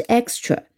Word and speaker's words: extra: 在 s 后面的清音、清辅音常常是要被读成浊辅音的，所以这里应该是 extra 啊，我们读出来是 extra。